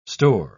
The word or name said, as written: store